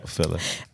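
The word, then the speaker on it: philia: Charity's given the romantic love, which is Eros, the brotherly love with this Philea. Is it Philea? I know what the word is, but I don't know if I'm pronouncing it right. Philla. – Yeah, Philla.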